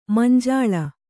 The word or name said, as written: ಮಂಜುಲ